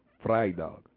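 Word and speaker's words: vrijdag: The V is pronounced F, you know what I'm saying? So when you see V, you want to pronounce it like F. So this is looks like Freidag, which is actually Freidag. – Freidag. – That's where we get Friday from. Freidag. V-R-Y-D-A-G.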